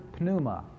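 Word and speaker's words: pneuma (πνεῦμα): is the word pneuma. It's spelled P-N-E-U-M-A, pneuma.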